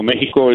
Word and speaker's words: Mexico: Mexico is the way it's pronounced, and it's the Spanish way of saying it.